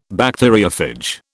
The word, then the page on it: Bacteriophage: British English